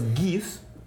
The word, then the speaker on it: GIF: No, no, no, no, Dato interesante: el creador de los GIFs insiste que se pronuncia GIF Aún, aún que sabe que a todo el internet le cae bien gordo que le digas GIF.